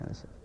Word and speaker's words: anise: I don't see a T in there. There's no T in there. – Anise.